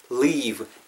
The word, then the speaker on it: leave: leave 발음하실 때도 L-E-A-V이잖아요. V 사운드 주의하셔야 되는 거 아시죠? 입술 다음 안 됩니다. 양입술. 윗니가 아래입술에 살짝 대시고 Don't leave. Don't leave the door 이런 식으로 발음해 주세요. 아시겠죠? 왜다 입술이 붙으면은 B 사운드로 느낄 수가 있다라는 것 때문이죠.